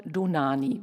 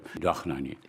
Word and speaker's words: Dohnányi: Ja, das kommt daher, dass an sich ist es doch Nani, wenn man es richtig ausspricht, und das wird auch dann nicht richtig ausgesprochen, ist an sich die ungarische Dohnányi.